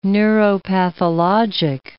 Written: neuropathologic

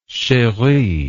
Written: cherie

